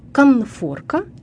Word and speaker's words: конфорка: Конфорка и конфорочный произносим и пишем с буквой «Н».